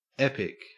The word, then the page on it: epic: Australian English